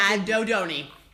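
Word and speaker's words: Addidoni: A-D-D-I-D-O-N-N-I-A. I'm like, Yep. – That's Adodoni. – That's exactly it. Rhymes with macaroni.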